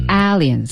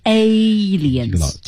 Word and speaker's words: aliens: 嗯，注意那个读音是 aliens, aliens. – 哎，它不是 aliens,、嗯、是 aliens。